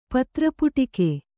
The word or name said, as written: ಪತ್ರಪುಟಿಕೆ